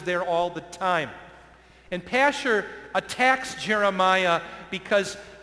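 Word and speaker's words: Pashhur: This guy's name is pronounced Pashur. You may as well know that. Let's say it with me, please. Pashur.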